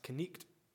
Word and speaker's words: knight: But in the medieval ages, they would have pronounced, a lot of people would have pronounced the word knight as knicht.